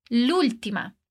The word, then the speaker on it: l'ultima: Perceba que a gente pronuncia tudo como se fosse uma palavra só. Mas, na verdade, temos o artigo la e a palavra última. O que acontece aqui é que a gente corta o A de la e emenda o L no última, l'ultima.